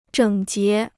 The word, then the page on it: 整洁: zhěng jié